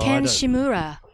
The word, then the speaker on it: Ken Shimura: Ken Shimura. – I don't know. – No, Japanese comedian. – Good pronunciation.